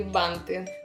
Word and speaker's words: банты: Предлагаю игру в фанты, а призами будут яркие банты.